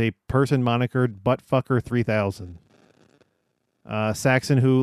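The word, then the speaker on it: Saxaon: Nathan Saxon, or how do you say that? – Saxon? – I guess it's supposed to be Saxon, but it's S-A-X-A-O-N. – Saxon, yeah.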